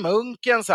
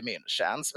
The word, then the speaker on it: Munkens: Men det har ju inte, det uttalades Munken, det vet jag från alla som arbetar där. Och det stämmer, man hade ju dåligt uttal. Så svenska som jobbar där, vanliga jobbare, de sa säkert Munkens bryggeri och inte Münchens.